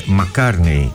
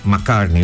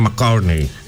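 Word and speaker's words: Mark Carney: Η φίλη μου η Παρασκευή με διορθώνει και μου λέει το όνομα του Καναδού Πρωθυπουργού είναι Μακάρνεϊ. Εγώ το είπα σαν Μακάρνεϊ, όχι, είναι Μακάρνεϊ.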